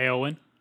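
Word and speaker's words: Éowyn: Is it A, Gandalf, B, Saruman, C, Oromir, or D, I don't know how to say it. It's like Eowyn? – Yeah, Eowyn. – Eowyn, yeah, okay.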